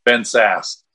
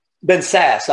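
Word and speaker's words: Ben Sasse: And Ben Sass. – Ben Sass.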